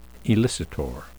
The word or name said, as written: elicitor